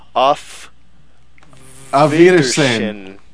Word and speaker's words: Auf Wiedersehen: Auf Wiedersehen.